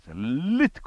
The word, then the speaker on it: Lift: スペースシャトルがバーッと上がるときね、その角を神戸を、そこで皆さんが Lift up your head、 Lift、この L をした、下の先を上顎につけて Lift up、この発音 OE gate、 O は常に O ですね。